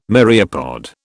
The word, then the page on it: myriapod: British English